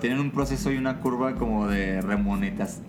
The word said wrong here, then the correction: remuneración: ¿cómo se dice? Remuneración.